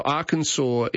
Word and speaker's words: Arkansas: So Arkansas is pronounced the way the French would have pronounced it, with the consonant being left off at the end.